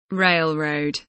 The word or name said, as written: railroad